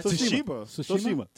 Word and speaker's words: Tsushima: Tsushima.